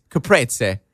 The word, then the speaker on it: caprese: My favorite thing to eat, and I ate it every day, was, and I'm going to pronounce this wrong, is it caprese salad? – Yeah, that's right. It's caprese. – Caprese. – Right, if you said it's named after Capri, the island of Capri, actually.